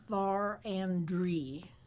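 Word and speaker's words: Verendrye: It's actually pronounced Varendry. – Varendry. – That was the French pronunciation.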